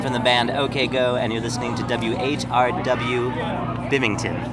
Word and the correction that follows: Binghamton: Is that right? – Binghamton. – How do you say it? – Binghamton. Yeah. – Oh, I spelled it wrong. How do you spell it? – B-I-N-G.